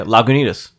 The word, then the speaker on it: Lagunitas: Yeah, Lagunitas.